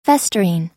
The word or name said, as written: festering